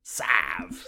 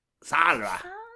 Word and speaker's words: salve: Salve. – Just pronounce the L really quickly and then it's sort of, it's almost like it's not there. – Salve.